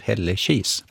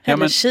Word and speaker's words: Hällekis: Och slår man upp Hällekis då, så står uttalet Hällekis. – Ja, nej, men jag ändrar mig alltså. Det är säkert så. Hällekis, ja, jag ska bara säga det nu.